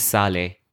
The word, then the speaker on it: Saleh: And if you say it, they can't, obviously there's a sa and a ha. – Yeah. – So that's very difficult. So I just say, I just say my name is Saleh.